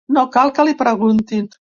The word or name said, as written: cal